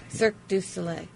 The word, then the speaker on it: Cirque du Soleil: OVO is, I can't say it right, Circus de Soleil. – Cirque du Soleil.